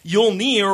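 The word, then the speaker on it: Jólnir: Yulnir, or Yulnir, spelled with a J, is just one of the many names used for the god we most commonly refer to as Odin.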